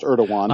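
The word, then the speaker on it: Erdoğan: And I don't even want to venture to try to pronounce his first and middle name, but his last name is pronounced Erdogan.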